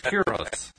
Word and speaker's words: gyros: It's well, I call it like I do, a gyro. – It's a gyro or a hero, but the Greek pronunciation is gyros.